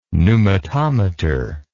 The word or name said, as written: pneumatometer